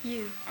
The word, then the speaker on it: ewe: And on the female, it's spelled E-W-E. How do you say it? – U.